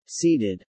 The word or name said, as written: seeded